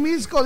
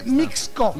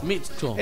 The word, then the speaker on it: Mixco: a Jorge Plata dice, por no pronunciar bien, Misco, dice Mixco. Mixco.